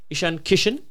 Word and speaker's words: Ishan Kishan: Ishan Kishan? – Yes.